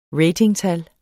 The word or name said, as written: ratingtal